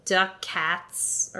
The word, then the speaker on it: ducats: with ducats, as it's actually pronounced.